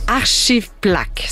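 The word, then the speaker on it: Arşiv Plak: Archive Plak, c'est comme ça qu'on prononce, hein? – Oui.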